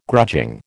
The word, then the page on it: grudging: American English